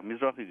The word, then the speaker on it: Mizrahi: and I probably will mispronounce this, but is it Mizrahi Jews? – Yeah, Mizrahi Jews.